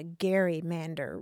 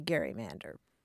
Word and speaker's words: gerrymander: And so for a long time, you were asking, why don't people say gerrymander? And indeed, for a long time, they did say gerrymander. – Really? – Yeah, yeah. And the pronunciation changed over time, I think, as the governor became less well-known. And also, the word itself was transmitted not by sound, but in print.